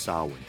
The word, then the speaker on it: Samhain: Its origins can be traced back to the Celtic Irish holiday of Samhain, spelled Samhain, actually pronounced Samhain.